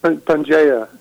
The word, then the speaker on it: Pangea: Pangea.